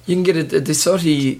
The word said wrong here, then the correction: DeSoto: DeSoti or DeSoto? – I don't know how to say it. – DeSoto. – Is it? Okay.